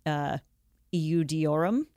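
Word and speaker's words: Iudaeorum: That's I-N-R-I. – In Latin, it's Jesus Nazarenus Rex Iudeorum. – Okay. Beautiful pronunciation. – Thanks. Iudeorum. Yeah, and then it's abbreviated to I-N-R-I.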